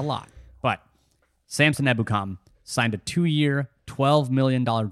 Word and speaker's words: Ebukam: We knew they were going to address this position at some point, and they did with former Los Angeles Ram Samson Ebukam. I've been thinking about pronouncing this name for so long. – Killing it.